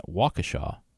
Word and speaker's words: Waukesha: during a recent visit to a school in Waukesha, and I do know how to pronounce that, to talk up his budget proposal.